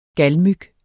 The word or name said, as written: galmyg